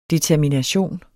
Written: determination

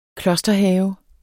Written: klosterhave

